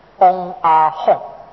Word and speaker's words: 嗡阿吽: 这三字的发音就是嗡阿吽啊，咱看咧，迄、那个印度文啊，顶冠会三字啊，这三字就是嗡阿吽。